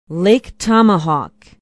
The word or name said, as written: lake tomahawk